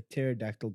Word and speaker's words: pterodactyl: Why can't you hear a pterodactyl going to the bathroom? – Why? – Because the pee is silent.